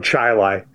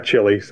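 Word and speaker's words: Chili: I grew up on a dirt road in the town of Chile, not Chile. – It's rhymes with highlight.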